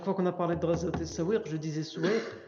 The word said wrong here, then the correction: Sawiq: La, prononciation, euh, la bonne prononciation, c'est As-Sawiq.